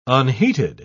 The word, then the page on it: unheated: British English